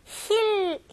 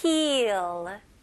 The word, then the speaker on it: heal: 여기서는 EA 발음은 E 발음이잖아요. 근데 조금 더 길게 해주세요. 힐, 힐링처럼 힐 이렇게 짧지는 않아요. 영어로는 heal 이렇게 고무줄 당기는 느낌으로 heal. heal. Okay, very good. 이 E 발음을 잘 해주셔야 됩니다.